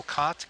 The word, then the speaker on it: Kott: That same evening in O'Shaughnessy Performance Space in Benilde's Hall on the College of Santa Fe campus, Martha Reich, R-E-I-C-H, is that how you pronounce it? – Yep. – And Michael Kott, K-O-T-T, which rhymes with not, N-O-T-T, who's kind of hot, H-O-T.